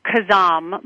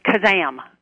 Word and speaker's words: Kazam: Well, it's correctly Kazam, but I like to say Kazam. It's more fun.